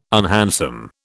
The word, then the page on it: unhandsome: British English